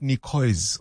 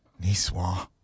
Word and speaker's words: niçoise: First time I thought, oh, is that how you say it? Not niçoise or niçoise. Niçoise.